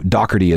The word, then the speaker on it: Doherty: Oh, by the way, uh, Doherty is how you do pronounce Pete Doherty's last name. That's the way the British do it.